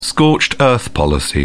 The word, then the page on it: scorched-earth policy: British English